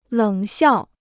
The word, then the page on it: 冷笑: lěng xiào